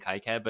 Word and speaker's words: KiCad: I say KiCad, but it's really KiCad.